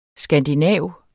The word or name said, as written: skandinav